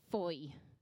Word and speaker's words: Fowey: For anybody that hasn't been to Foy, it's spelled F-O-W-E-Y.